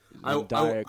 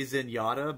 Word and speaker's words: Zendaya: Zendaya I, I, I, like naturally want to say Zenyatta, but I know obviously that's not right.